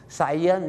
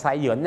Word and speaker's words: science: คำที่หนึ่งครับของเราวันนี้ก็คือคำว่าวิทยาศาสตร์ครับได้ยินประจำนะครับหลายคนไปออกเสียงว่าทรายถ้าไปออกเสียงว่าทรายเหมือนกับทรายที่อยู่ที่ท้องทะเลนะครับจะแปลว่าอะไรไหมครับบางคนไปบอกฝรั่งว่า I study sign ก็แปลว่าผมศึกษาป้ายอยู่ป้ายอะไรไม่ทราบครับที่จริงแล้วนะครับวิทยาศาสตร์นะครับไม่ยากครับก็คือออกเสียงว่าทรายแล้วก็อย่างนี้ครับ Science นั่นเองครับ Science เนี่ยคล้ายๆเหยินเนี่ยนะฮะ Science นั่นเอง